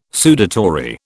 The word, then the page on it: sudatory: British English